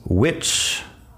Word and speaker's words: which: Tenemos which, which de cuál. Tenemos where de dónde. Tenemos when de cuándo. Tenemos what de qué. Estas son palabras con la WH, que la H no suena como si solo estuviera ahí la W.